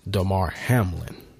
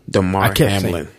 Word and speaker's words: Damar Hamlin: It's Damar Hamlin. – Damar Hamlin.